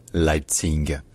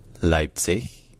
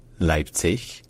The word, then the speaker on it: Leipzig: Alors, cette ville allemande, j'entends certaines personnes en français la prononcer Leipzig, mais en allemand, ça ne se prononce jamais comme ça. Ça se prononce soit en allemand standard, y compris dans la ville elle-même, Leipzig, Leipzig, avec le son ch à la fin,